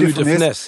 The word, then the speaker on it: Funès: Ich habe immer Louis de Funès gesagt, aber das S wird am Ende mitgesprochen. Also, also Louis, Louis de Funès. De Ah, okay. Louis de Funès, genau.